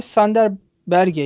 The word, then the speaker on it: Sander Berge: Sander Berge yani ismi nasıl okunuyor tam bilmiyorum lütfen düzeltin yanlışsa. – Yo doğru.